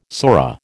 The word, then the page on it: sora: American English